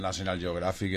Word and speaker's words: National Geographic: National Geographic a finales del año pasado ya.